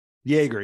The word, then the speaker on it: Yager: Yager, yep.